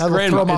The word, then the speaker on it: grandma: The D is silent. – It's grandma. It's grandma.